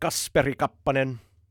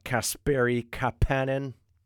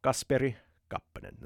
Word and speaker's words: Kasperi Kapanen: Edmonton's got Kasperi Kappanen, not Kasperi Kapanen. Kasperi Kappanen. The trick to Finn is you pronounce the first syllable strong, second lower, third then middle, and you have to determine if it's a compound name. If it's long, it probably is.